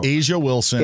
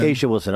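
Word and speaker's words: A'ja Wilson: Is it Ajay Wilson? I don't know. – Asia Wilson. – Asia Wilson. I'm sorry.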